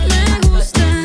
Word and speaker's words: Me Gusta: Me gusta cuando yo te tengo como yo te trago el mundo. Desnuda, de donde salió tanta mala y tanta ricura. Me gusta eso que me dices, pero sé que es una excusa.